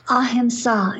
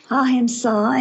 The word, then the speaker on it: Ahimsa: The first one, Ahimsa. – Ahimsa is the Sanskrit word. Ahimsa, and it means literally do no harm.